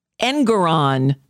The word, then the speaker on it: Engeron: And he set aside Judge, I always want to say Erdogan. It's not Erdogan. And I thought I spelled it out phonetically and I still can't find it. Engeron. See, that's why I get it mixed up. Engeron, not Erdogan.